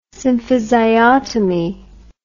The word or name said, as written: symphysiotomy